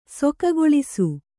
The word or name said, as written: ಸೊಕಗೊಳಿಸು